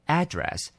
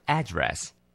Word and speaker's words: address: 这个字就叫做地址 address, 你可以念成 address, 也有人念成 address, 都可以的。